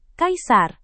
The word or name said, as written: kaisar